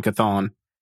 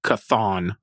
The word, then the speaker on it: Chthon: Yes, I think that is the correct pronunciation, K'thon. – It's spelled C-H-T-H-O-N. – Yeah. – So it's like, yeah, it's like Cthulhu, but K'thon.